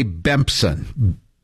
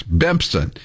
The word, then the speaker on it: Bempson: But first guy, the leader of the gang is Mackenzie Ray Bempson. rhymes with Simpson, as in the Simpsons. Bempson, a.k.a.